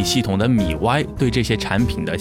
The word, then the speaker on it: MIUI: 在这里呢，统一给大家科普一下，这个系统呢，在小米之前的发布会上，雷军呢是把它念作了米 Y，当然米 Y 也就是对小米系统的正解了。